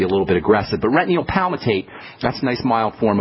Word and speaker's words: retinyl palmitate: It's called retinyl palmitate.